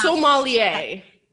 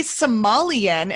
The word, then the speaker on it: sommelier: It's sommelier. – Then right? – Some people say Somalian and I'm like, that's like way off.